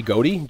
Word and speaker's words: Godey: Yeah, I would say Gody, G-O-D-E-Y, Gody, Gody.